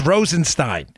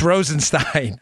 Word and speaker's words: Rosenstein: It's Rosenstein. Trust me, it's Rosenstein. I know you keep, I've gotten this from so many people, it's Rosenstein. I say it wrong all the time, it's Rosenstein.